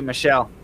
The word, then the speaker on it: Michel: My dad's name is actually Michel, Michael when you pronounce it in English.